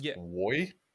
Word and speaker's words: Roy: It's pronounced Woy. – Oh, sorry, Woy.